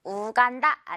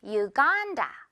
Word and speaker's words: Uganda: 두 번째로는 우간다 아니고요. U 발음이고요. 간다. 이렇게 간 부분 강세 주면서 길게 끌어주면 돼요. Uganda.